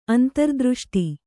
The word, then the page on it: ಅಂತರ್ದೃಷ್ಟಿ: antardřṣṭi